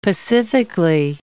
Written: pacifically

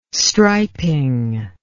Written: striping

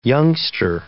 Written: youngster

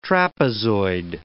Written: trapezoid

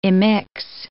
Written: immix

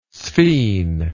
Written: sphene

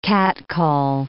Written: catcall

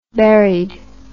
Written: buried

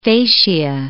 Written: fascia

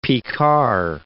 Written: Picard

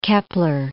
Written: Kepler

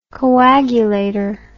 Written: coagulator

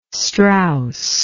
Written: Straus

